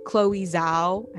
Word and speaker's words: Chloe Zhao: Chloe Zhao, I believe that's how you say her name, right? Yes. Chloe Zhao, like just make it up there and like, you know, like win these awards in these fields.